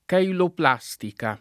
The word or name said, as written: cheiloplastica